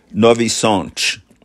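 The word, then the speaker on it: Nowy Sącz: The spelling in English and in Polish is N-O-W-Y space, capital S-A with a diacritical mark underneath it, C-Z. And it's pronounced Novi Sad in Polish.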